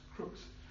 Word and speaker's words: crux: And it's spelled C-R-U-X, but it's pronounced crooks.